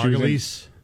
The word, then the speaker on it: Margolyes: Margolyes. I don't know. – Margulies. – Margulies? Is that how you say it? – Yeah.